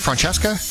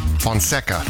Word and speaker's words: Fonseca: Francesca? – No, Fonseca. – Fonseca.